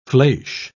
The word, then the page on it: fleche: British English